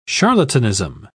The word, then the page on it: charlatanism: British English